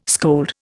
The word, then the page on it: scold: Indian